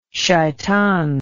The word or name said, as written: sheitan